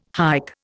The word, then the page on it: hike: Indian